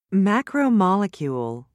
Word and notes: macromolecule: American English

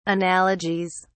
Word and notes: analogies: British English

